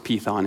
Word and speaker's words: Python: By the way, spelt Python, pronounced Python in Greek.